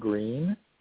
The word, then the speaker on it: Green: Green, with an E at the end, .info.